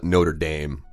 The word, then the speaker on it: Notre Dame: Well, so they call it Notre Dame. – Oh, it's a very Americanized French pronunciation, right?